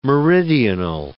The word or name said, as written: meridional